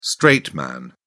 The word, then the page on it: straight man: British English